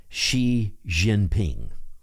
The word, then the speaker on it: Xi Jinping: Here's what's helping Xi Jinping.